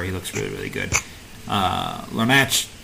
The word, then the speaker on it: Larnach: I always want to put the Larnach, but it's Larnach. Larnach.